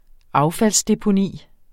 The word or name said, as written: affaldsdeponi